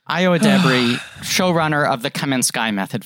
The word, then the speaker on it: Edebiri: I'm going to say one thing that is so, it's a Edebiri.